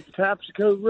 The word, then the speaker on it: Patapsco: That's what it is, the Patapsco River, right here at Fort Armstead.